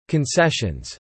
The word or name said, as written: concessions